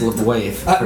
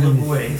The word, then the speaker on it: LaBeouf: It's pronounced LaBeouf. – Number one. – LaBeouf.